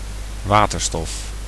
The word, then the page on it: hydrogène: [idʁoˈʒen]; /id.ʁɔ.ʒɛn/; /i.dʁo.ʒɛn/